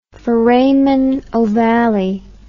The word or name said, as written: foramen ovale